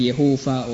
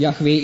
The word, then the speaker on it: Yahweh: Kann man Vokale einsetzen, Jehova oder Yahweh. Und Yahweh ist die ursprüngliche Aussprache, sehr wohl.